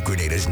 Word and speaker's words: Grenada: So today, like we Germans say, we are in Grenada. But I heard in English it's called Grenada. – It's not Grenada. It's Grenada. Say it right.